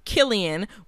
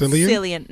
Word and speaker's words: Cillian: So, Killian, which is how you say his name, not Cillian. No, Cillian? It's Killian.